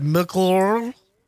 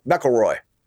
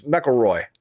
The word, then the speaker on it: McIlroy: How do you even pronounce his last name? – Let me see here. It's McIlroy. What's that? McIlroy? McIlroy? – Oh, McElroy. – You just make up a bunch of vowels to pronounce it? – No, it's McElroy. You kind of say it like that, yeah.